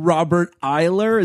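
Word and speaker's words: Robert Iler: One of my favorite parts of this flashback, by the way, we got a young Robert Eiler.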